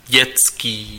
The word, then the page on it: enfantin: France; /ɑ̃.fɑ̃.tɛ̃/